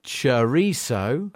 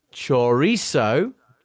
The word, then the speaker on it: chorizo: The usual pronunciation in English is chorizo. Although chorizo, chorizo and chorizo, as in sorry, are also certified as pronunciations in British dictionaries.